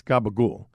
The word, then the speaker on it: Capicola: First one up is the Capicola, which that's what Americans say, but for us Italians, it's Gabagool.